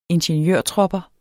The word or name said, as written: ingeniørtropper